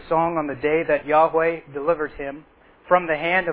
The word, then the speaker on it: Yahweh: You'll see, probably see "of the Lord" in capitals, but I'm going to pronounce that as Yahweh, as the original Hebrew is indicating there.